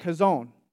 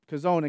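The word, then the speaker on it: kazon (חזון): The word for vision in this verse is a Hebrew word, and you pronounce it kazon, kazon.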